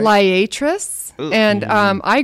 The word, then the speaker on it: Liatris: How do you say L-I-A-T-R-I-S? – Liatris.